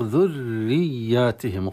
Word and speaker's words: ذرياتهم: يقول لك ذرياتهم بسبب الشد في الراء، وشد صعبة هنا لأن شد في الراء المكسورة طلب الترقيق الراء وهي مسبوقة بضمة، فنطقها في هذه الحال في الصعود، ثم بعدها يا مشددة والياء يتطلب جريان الصوت فتنطق ذرياتهم، قليل من ينطقها هكذا البريق ذرياتهم ذرياتهم شدتين راء مشددة ويا مشددة.